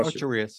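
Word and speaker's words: Ocho Rios: and they swapped it out for Osorios. Is that how you pronounce it? Jamaica? – Yeah. – So they swapped it out for Osorios.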